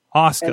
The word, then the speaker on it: Asuka: No, the reason I messed up with Asuka is there was a character in Street Fighter that had the same name, and I've been mispronouncing it for like 20 years.